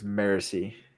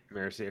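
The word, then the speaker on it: Marasi: Okay, how was it pronounced again? Is it Marassi? – The audiobook is Marassi. – Marassi, okay. So Marassi is taken hostage.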